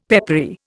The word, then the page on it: peppery: Indian